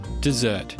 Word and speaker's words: desert: But when the word desert is used as a verb, meaning to run away, it's pronounced desert.